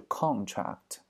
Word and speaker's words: contract: 郎平的 contract 啊，当名词读作是 contract。